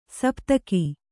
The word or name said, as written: ಸಪ್ತಕ